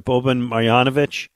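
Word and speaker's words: Boban Marjanović: I love, I'm going to say the name right, Boban Marjanovic. – Boban, big, yes.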